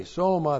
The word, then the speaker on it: soma: Looks like that in the Greek, S long O M A, soma, that's the word for the body.